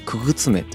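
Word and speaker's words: 傀儡女: 遊女と書いて遊び目とか、あとは傀儡の女と書いてくぐつめと呼ばれるそうなんですよ、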